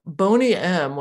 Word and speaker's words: Boney M: Boney M was the name of the group. I think it's pronounced Boney-M.